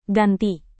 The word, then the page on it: ganti: Indonesian